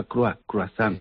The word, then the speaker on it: croissant: En inglés tú dices croissant, por ejemplo, escribes croissant, pero esa OI se pronuncia croissant.